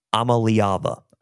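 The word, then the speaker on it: Iamaleava: But Ramel Keaton could be an option for the newly touted quarterback, Nico Liava last year. So this is how you pronounce it. Let me just clear it all. Nico Amaliava. Amaliava.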